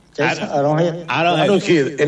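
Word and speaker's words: Arrowhead: En el estadio Aaron Head. – En el estadio Aaron Head, aquí es el estadio, ¿lo dije bien? – Aaron Head.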